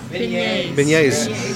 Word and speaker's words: beignets: They do, am I saying this right? Beignets? – Beignets.